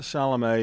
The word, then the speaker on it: Chalamet: Chalamet is the T is like silent.